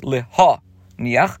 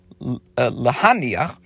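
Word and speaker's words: LeHaniach (להניח): The word LeHaniach should be pronounced with a komatz under the hay. Again, that's LeHaniach, and not with a patach, which would be LeHaniach. For this would imply abandoning the tefillin, as in the verse Kamish Kosev, Achichem Ha'echad Hanichu Iti.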